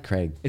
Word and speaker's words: Cragg: I like Craig. It's no, it's Craig. I, like, like the Aggro Crag from Nickelodeon Guts. – I don't know that it's pronounced that way. I think it's Craig. No, it's Craig. – It's literally C R A G G. – There's no I.